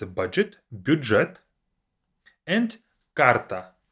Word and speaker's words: карта: Card is карта, we have the I at the end, карта.